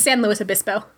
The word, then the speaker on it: San Luis Obispo: It's San Luis Obispo. Sorry. – Obispo, yeah, okay. Yeah, no, I've, like, never heard of that place before. They're like, Obispo? – Yeah, it's spelled weird, but you just gotta say it with confidence and just, it doesn't look like it sounds.